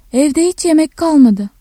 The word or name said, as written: yemek